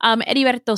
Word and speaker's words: Heriberto: Heriberto Seda, a.k.a. Eddie, or Eddie, again, the H is silent, and when the two R's... I'm not going to give you a Spanish lesson.